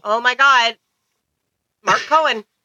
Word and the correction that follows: Cohn: Or, I'm sorry, Cohn. I would want to pronounce it wrong. Mark Cohn.